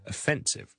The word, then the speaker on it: offensive: 好多时候呢唔系重音嘅音节呢个 O 呢我都会读成呢系一个 schwa, 弱嘅，因为佢本身系一个好弱嘅音节，所以呢就 offensive。